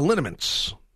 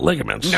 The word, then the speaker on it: liniments: No, I just take a lot of liniments, not ligaments.